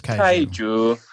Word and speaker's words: Kaiju: K-A-I-J-U. I'm pretty sure it's Kaiju.